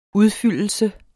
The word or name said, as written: udfyldelse